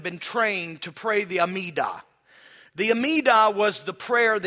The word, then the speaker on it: Amida: You spell it A-M-I-D-A.